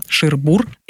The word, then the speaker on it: Шербур: Кстати, именно так стоит ставить ударение. Шербур.